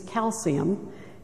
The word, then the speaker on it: Calciu: It means calcium, and it's spelled like calcium without the M, Calciu.